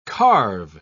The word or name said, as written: carve